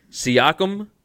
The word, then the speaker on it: Siakam: Siakam for the Raptors? – Yep.